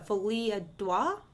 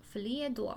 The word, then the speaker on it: folie à deux: another theory, which seems to me the most plausible, is called a folie à deux. It's French. – Folie à deux. – F-O-L-I-E space A space D-E-U-X.